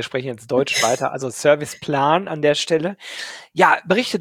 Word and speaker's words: Serviceplan: Sagt ihr Serviceplan oder Serviceplan? – Beides, aber Serviceplan ist weiter verbreitet. Das andere, Serviceplan, wird eher von den englischsprachigen Kollegen und Kolleginnen genutzt.